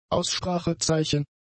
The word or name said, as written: Aussprachezeichen